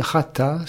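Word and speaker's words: chata (חטא): Le péché, c'est chété, chété en hébreu, un ret, un tet et un aleph. Prononcer chata, cela signifie échouer, manquer.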